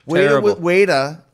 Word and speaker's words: Ueda: Weta, which is spelled UEDA, was a kickboxer who had zero wrestling experience, didn't want wrestling experience.